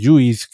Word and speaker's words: JUICE: Pero, Aunque en inglés se pronuncia juice.